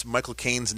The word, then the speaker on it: Michael Caine: The best way it is just pronounce the words my and the word like a drug cocaine.